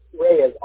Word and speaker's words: Reyes: Reyes, R-E-Y-E-S. First name is spelled I-R-V-I-N.